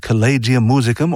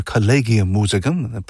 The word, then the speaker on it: Collegium Musicum: Georg Philipp Telemann ran an orchestra that he called Collegium Musicum, or Collegium Musicum, pronounced both ways.